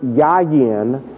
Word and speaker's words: yayin: The Hebrew word, the first word is the word yayin. It's spelled Y-A-Y-I-N. Yayin.